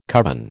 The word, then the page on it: coven: American English